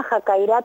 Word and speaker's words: Jakairá: Bueno, mira, nosotros somos el programa Jacaira Tras la Sierra, así con acento en la A.